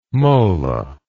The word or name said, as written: mola